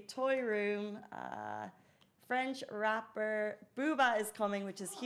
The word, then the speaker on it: Booba: I think it's uh, French rapper, uh, rapper Boomba. Oh my God, I've said the name wrong. Excuse me. Booba? – Booba, Booba is coming.